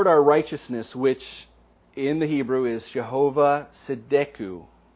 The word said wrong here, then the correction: Tzidkenu: I probably said that wrong. Tzidkenu. There we go. Tzidkenu.